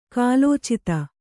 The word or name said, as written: ಕಾಲೋಚಿತ